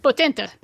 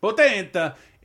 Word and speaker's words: Potente: Franca Potente. How do I say that time? – Potente. – Potente.